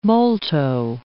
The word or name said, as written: molto